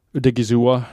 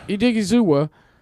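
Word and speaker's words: Odighizuwa: Odigizuwa. – Odigizuwa.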